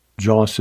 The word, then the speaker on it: Joss: Joss is often said to be a corruption of the Portuguese word Dios, but it is not a corruption. Instead, it is the way most Brazilians and some European Portuguese pronounce the word, for whom the letter D is sounded like a hard J when it precedes an I.